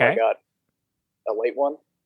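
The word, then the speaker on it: Ale-8-One: So it's like, it's Ale 8-1, but it's pronounced a late one.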